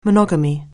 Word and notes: monogamy: anglais

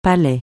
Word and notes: palais: francais

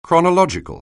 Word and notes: chronological: anglais

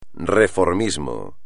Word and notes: reformismo: espagnol

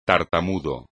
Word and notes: tartamudo: espagnol

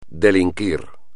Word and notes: delinquir: espagnol

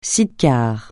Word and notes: Sida: francais

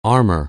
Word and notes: armor: anglais

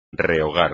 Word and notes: rehogar: espagnol

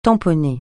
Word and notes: tamponner: francais

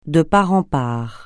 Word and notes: de part en part: francais

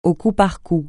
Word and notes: au coup par coup: francais

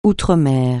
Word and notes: outre-mer: francais

